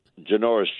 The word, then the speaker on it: Janoris: Is Janoris, is that how you pronounce this guy's name? – Janoris Jenkins, yes.